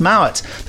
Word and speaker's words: Mowat: I must be careful to pronounce his name correctly, because I know a person called Sarah Mowat, and her name is spelt exactly the same. But she says Mowat, he says Mowat.